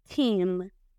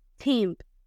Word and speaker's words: team: Se dice team, team. Aquí está el ditongo EA que se convierte en sonido de I, team. Como les dije, todo esto tiene una explicación si aprenden las reglas de pronunciación de las vocales y los diptongos que yo les enseño en el curso completo. Team y que suene el anime, ¿ok?